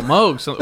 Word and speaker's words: Moog: Moog. Ooh, holy smokes. I'm already just messing it all up. I thought it was Moog. It's spelled M-O-O-G. – I know. – And I'm being corrected by, you know, this man.